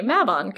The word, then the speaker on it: Mabon: So for future reference, and for me for the rest of my life, I'm going to try my hardest to say Mabon, because that is the correct way to say it.